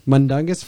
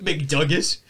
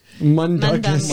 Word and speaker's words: Mundungus: Mundungus Fletcher, right? – What? – That's how you say it. – McDungus? – Mundungus.